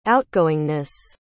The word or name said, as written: outgoingness